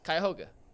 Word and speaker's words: Cuyahoga: I just asked Anthony how to say it. – Yeah, but now you forgot it. – And they both said something different, so it really threw me off. But it's Cuyahoga.